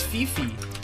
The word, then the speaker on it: Fify: It's Fifi. Except it's spelled F-I-F-Y.